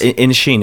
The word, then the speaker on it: Inisherin: The Banshees of Inisherin, which is by your director. – Yes. – Inisherin, is that how you pronounce Inisherin. – It? – Inisherin. – The Banshees of Inisherin.